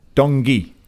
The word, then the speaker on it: Tanguy: Guardian today reporting, amongst other people, that Spurs are making a between 55 and 65 million bid for Tungay and Ndombele. It's been Tungay and Ndombele. – Dongi. – Okay. Dongi.